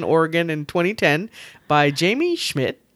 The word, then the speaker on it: Schmidt's: It's Schmitz. – Schmitz. – You know my issue with pronouncing things. – Schmidt. Yes. Now I can't say it. Thanks. SCHMIDT'S. There you go.